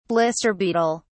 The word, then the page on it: blister beetle: British English